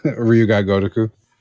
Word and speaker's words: Ryu Ga Gotoku: How do you actually say that? You probably actually heard it pronounced. Ryu Ga Gotoku.